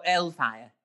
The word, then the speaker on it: Hellfire: You wouldn't pronounce the H, so it'd be Hellfire. – Oh, Hellfire.